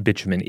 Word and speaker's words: bitumen: First, just a reminder on what bitumen is. – It's the word I can never remember how to pronounce the American way. I think we say it the British way, which is bitumen.